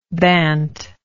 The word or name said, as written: bant